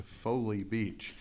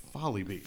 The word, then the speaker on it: Folly: Somebody says that they want to go to the beach, and they were thinking about going to Foley Beach. But the word is spelled F-O-L-L-Y. – Folly. – Folly Beach. – Folly Beach. – Just like Seward's Folly or any other word, anytime you've seen that word, it's pronounced the exact way it always has been, Folly Beach.